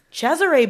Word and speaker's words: Cesare: Cesare Borgia. Sorry, Cesar Borgia. They call him Cesare in this. – I mean, that's probably more accurate. I'm pronouncing things more French than Italian.